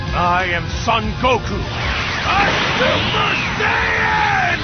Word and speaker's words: Son: This is just the short one. Well, what's important to know is just, it's what we would consider the long O sound, but just a shortened version of it. O. Son. That's all there is to it.